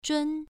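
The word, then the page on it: zhun: tone 1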